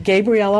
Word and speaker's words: Gabriele: Gabriele on the Road, which is G A B R I E L E on the Road on YouTube.